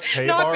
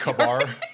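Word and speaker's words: KA-BAR: Yeah, so I know it says K-bar, but it's pronounced Kabar.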